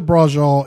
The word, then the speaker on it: braciole: Braciole, I think it's B-R-A-C-I-O-L-E. Braciole. And it's Italian, I believe. – You had mentioned it earlier. It sounds really delicious. – Yeah, so essentially... Well, essentially, braciole is,